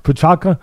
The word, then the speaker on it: Prochazka: Am I saying it right now? Prochazka? – Yeah.